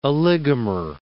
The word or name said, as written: oligomer